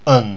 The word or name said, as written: un